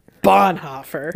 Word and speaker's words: Bonhoeffer: And you have to say it like that. Bonhoeffer. – That's right. – Because it's German. – Because it's German. So it's a little angry. A little angry in there. Mm-hmm.